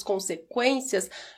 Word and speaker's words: consequências: Quando nós falamos consequências, nós pronunciamos a letra letra U.